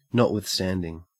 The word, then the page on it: notwithstanding: Australian English